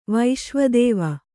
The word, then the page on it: ವಿಶ್ವೇದೇವ: viśvē dēva